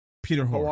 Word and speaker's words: Hoare: Is it pronounced Hoare? – Peter Hoare. – Hoare. Hoare. So this is what I found.